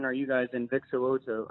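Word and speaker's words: So'oto: And you got his name right, Vic Siloto.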